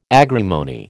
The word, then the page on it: agrimony: American English